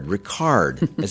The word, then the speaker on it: Reichardt: Am I saying your name correctly, Reichardt? – Ricard, as in Ricardo Montalban.